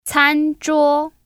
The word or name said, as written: CanZhuo